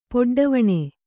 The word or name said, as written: ಪೊಡ್ಡವಣೆ